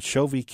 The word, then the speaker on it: Chauvet: the Chauvet Cave in France.